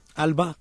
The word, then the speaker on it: ALBA: ¿lo he dicho bien? – ALBA, correcto.